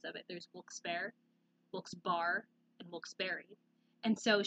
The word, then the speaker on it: Wilkes-Barre: But according to the article, the correct way to pronounce it is Wilkes-Barre.